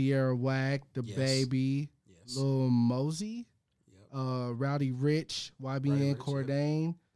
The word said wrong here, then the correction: Cordae: Cordae, sorry.